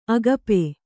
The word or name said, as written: ಅಗಪೆ